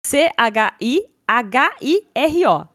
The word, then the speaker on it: Chihiro: Chihiro se escreve C H I H I R O. Então é Chihiro, só que o ri ali é com H, né, na, na pronúncia.